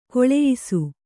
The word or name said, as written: ಖುಲಾಯಿಸು